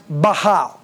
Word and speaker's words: Baal: It's actually pronounced Baal, but we'll call them prophets of Baal.